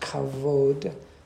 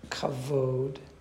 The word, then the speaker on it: kavod: Now, let's look at this one word here, pronounced kavod, kavod.